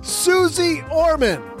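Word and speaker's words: Suze Orman: What do you mean that's not how you pronounce it? Susie? Okay, got it. As I was saying, Susie Orman.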